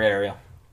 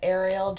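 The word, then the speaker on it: Aerial: Go for Ariel. – You can find me on Instagram at Ariel J. Washington. That's A-E-R-I-A-L.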